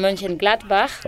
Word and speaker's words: Mönchengladbach: en Mönchengladbach.